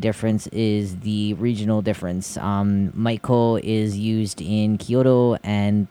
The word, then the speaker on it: Maiko: Maiko is spelled M-A-I-K-O, Maiko. Or if you're going with the variation in Tokyo, it would be Hangyoku. H-A-N-G-Y-O-K-U.